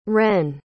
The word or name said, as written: rennes